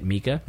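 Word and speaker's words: Micah: And then Mika Sloat, who plays Mika, which I thought that was cool, too, because it's spelled Mika, but you say it Mika.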